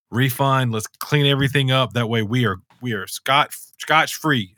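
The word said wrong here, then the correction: scot-free: Scotch free or scot free? – Scot free. – Scot free, not scotch.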